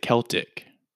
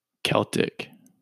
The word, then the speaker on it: Celtic: And then we have Celtic, S-C-L-T-I-C. So in England and Wales, they pronounce it Celtic. Celtic.